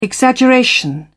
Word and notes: exaggeration: British English

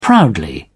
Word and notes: proudly: British English

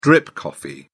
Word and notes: drip coffee: British English

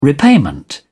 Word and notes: repayment: British English

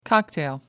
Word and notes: cocktail: American English

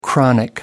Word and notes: chronic: American English

